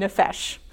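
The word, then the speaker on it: nephesh (נֶפֶשׁ): And that was called, let me make sure I get this right, nephesh. You would not think that from looking at that, but I have asked a Hebrew scholar, and nephesh is how you pronounce that.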